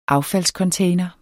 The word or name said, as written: affaldscontainer